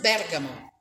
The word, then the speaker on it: Bergamo: You have to pronounce it Bergamo. So the stress is on the first syllable, Bergamo.